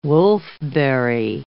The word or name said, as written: wolfberry